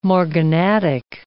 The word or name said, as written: morganatic